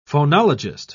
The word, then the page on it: phonologist: British English